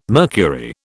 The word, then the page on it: mercury: British English